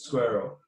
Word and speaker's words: SQRL: which is SQRL. It's pronounced Squirrel.